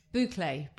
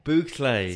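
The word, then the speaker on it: bouclé: How do I pronounce this word? Buchel. – Boucle. – Boucle. – So tweed is known in France as boucle.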